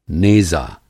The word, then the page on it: नेज़ा: Hindi